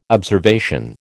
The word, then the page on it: observation: American English